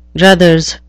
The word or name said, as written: druthers